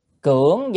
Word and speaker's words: cưỡng: Cưỡng danh, chữ này đã phải đọc là âm là cưỡng chứ không đọc cường được.